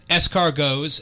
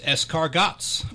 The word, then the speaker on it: escargots: that he would pronounce escargots, escargots, which always gets me to giggle.